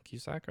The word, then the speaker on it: Cusack: played by John Cusack, right? – Yeah. – That's how you say it.